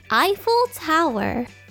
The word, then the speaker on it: Eiffel Tower: 正しくは The Eiffel Tower The Eiffel Tower と発音します